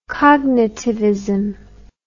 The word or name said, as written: cognitivism